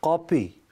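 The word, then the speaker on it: kapı: تو یہ جو قلعہ ہے، اس قلعے پر ایک توپ نصب تھی، اور ترکی زبان میں کاپی، اب اس کے تلفظ میں بھی لوگ غلطی کرتے ہیں، اصل میں کلفی والا قاف ہے.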